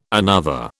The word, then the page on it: another: British English